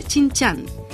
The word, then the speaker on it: Shijingshan: Chinchan, que se escribe S-H-I-J-I-N-G-S-H-A-N, número 16A, Beijing, código postal 100.040.